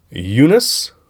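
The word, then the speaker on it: Joonas: And it is Eunice. Eunice. Okay. Eunice. Starts with a J, but the J is pronounced Y.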